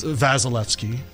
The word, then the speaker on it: Wasilewski: It's Wasilewski, because it's Polish.